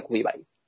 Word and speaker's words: Quibi: Se escribe Kiwi, pero no como los kiwis, sino Q-U-I-B-I que parece ser que se pronuncia Kubibai.